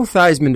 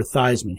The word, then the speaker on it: Theismann: It was never Theismann. He changed that so that it would rhyme with Heisman, like the Heisman Trophy.